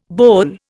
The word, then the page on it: boulle: Indian